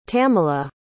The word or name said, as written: tamela